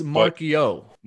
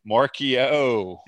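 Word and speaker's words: Marchiol: Nico Martial, am I saying that right? That's your guy. – It's Marquio. – Marquio. – Yeah.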